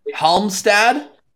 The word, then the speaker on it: Halmstad: Last pronunciation, Halmstad? – Yeah.